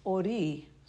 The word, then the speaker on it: ori: It's ori, so thank you, Rosemary, for correcting my pronunciation of it.